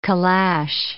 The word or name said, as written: calash